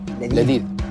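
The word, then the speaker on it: Ladith (لذيذ): How do you say delicious in Arabic? – Ladith. – Ladith? – Ladith, yeah.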